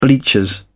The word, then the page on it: bleachers: British English